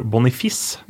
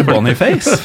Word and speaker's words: Boniface: Veldig spent på den nye, nye, nigerianske spissen, som visstnok skal uttales Victor Bonifis, Mm. Så han bør ja, ikke spille i Danmark. Mm. Uh, eh, det må være Boniface. For, for, nei, det er visst Bonifice. Eller Uh, for det har min kollega Asbjørn Myhre spurt han om på flyplassen okay. til, på vei til Marbella, at det er Bonifis som um, er uttalen der.